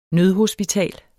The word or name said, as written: nødhospital